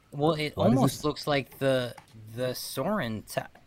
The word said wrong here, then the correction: Sauron: That's not his name. How do you say the guy's name? – Sauron.